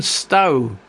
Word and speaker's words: Stough: And I Stow or Stow, I think you pronounce it, S-T-O-U-G-H, it's spelt anyway, Looking for Home, it's an EP, and that was the title track.